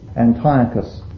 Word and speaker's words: Antiochus: Antioch was the capital, and the kings who reigned, many of them took the name Antiochus, or Antiochus, to give it its correct pronunciation, Antiochus, based on Antioch.